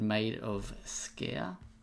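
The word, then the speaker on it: Maid of Sker: So I've been playing a horror game called Made of Scare.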